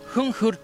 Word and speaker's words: Huun Huur: Estamos acá en Revolución Folk con nuestros amigos de Junjur Tour. ¿Cómo? What's the pronunciation exactly? – Yeah. – Junjur Ok, very good.